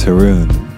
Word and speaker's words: Haroon: My name is Haroon, and that's spelled H-A-R-O-O-N.